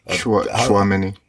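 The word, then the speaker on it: Tchouaméni: Is it Chumini or Tachumini? Tchouameni. Tchouameni, yeah.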